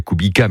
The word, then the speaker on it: Kubica: Robert Kubica, ça s'écrit Kubica, mais ça se dit Kubica, polonais qui a gagné avec Ferrari.